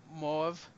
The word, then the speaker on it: Moev: Mauve, M-O-E-V. – Oh, Moev. – Yeah, Moev. Do you call them Moev? – Yeah, I've seen them live. I'm pretty sure it's pronounced Moev.